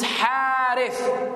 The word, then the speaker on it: Harith: When someone's name is Harith. Huh? H, ha alif ratha. If you write it in Arabic, H A R I T H, it's harith. Or if you say harith, why didn't you say harith?